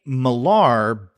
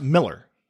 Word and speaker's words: Millar: Yeah, I've heard people say Millar, but I think maybe even him say that because I think he's Scottish, right? – But it's pronounced Miller.